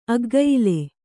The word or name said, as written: ಅಗ್ಗಯಿಲೆ